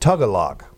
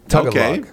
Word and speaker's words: Tagalog: The language is Tagalog, pronounced Tagalog. Tagalog.